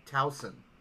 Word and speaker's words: Towson: Townsend? – It's pronounced Towson, but... or Towson, I'm not sure. – I think it's Towson. Shin's like, something Eagles? Yeah, exactly. – But it's... It's spelled T-O-W-S-O-N, like Towson.